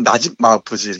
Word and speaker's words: 나집 마우프지: 정확히는 나집 마우프지 이렇게 발음하는데요.